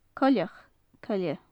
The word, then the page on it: coileach coille: gaelic